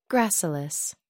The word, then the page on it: gracilis: American English